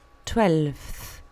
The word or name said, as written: twelfth